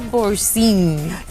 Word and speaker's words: Boursin: Vamos a hacer un orzo con queso boursin.